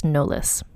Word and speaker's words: Knollys: So her last name is Knollys, or it's spelled K-N-O-L-L-Y-S, and I believe it's pronounced Knollys, like the same way as Beyonce Knollys Carter.